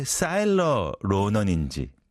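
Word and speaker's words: Saoirse: 예를 들어서 Saurus로는 S-A-O-I-R-S-E-R-O-N-A-N 이 왜 Saurus인지 그 다음에 Killian Murphy C-I-L-L-I-A-N Murphy가 왜 Killian Murphy인지 Cillian이 아니고 말이죠.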